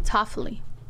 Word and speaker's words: Toffoli: Say it right. – Uh-huh. Talofi? How do you say it? – So we gave you the goods? – Yeah. Toffoli. Toffoli. – Toffoli. Not Toffoli. What did I say? Talofi? – Talofi, yeah. – Why did you say it? – You got close.